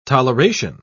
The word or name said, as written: toleration